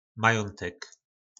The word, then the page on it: majontek: po našimu